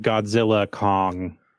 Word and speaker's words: Godzilla x Kong: Yeah, I think it's just like Godzilla Kong the New Empire. That's the thing, that's technically the way it's pronounced. – Okay, like you don't pronounce the X, but I know that's kind of weird.